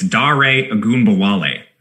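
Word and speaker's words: Dare Ogunbowale: So please, if you're listening, Dare, is it Dare or Dare? – Oh, I got you, man. I went to Wisconsin. It's Dare Agumbale.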